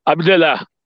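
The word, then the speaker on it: Abdellah: A gente foi primeiramente no estádio Agora se liga na pronúncia, hein? Prince Mulher Abdelé. Prince Mulher Abdelé, Abdelá. Aí, Abdelá que nós fomos e, e, e, observamos.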